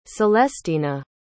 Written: celestyna

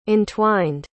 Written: entwined

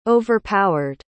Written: overpowered

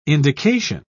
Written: indication

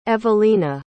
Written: evelena

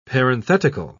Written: parenthetical

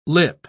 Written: lip

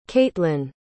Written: catelyn

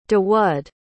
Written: dawud

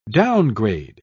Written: downgrade

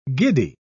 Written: giddy

